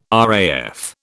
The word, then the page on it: RAF: British English